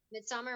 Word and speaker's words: Midsommar: Midsommar, am I saying that right? – Yes.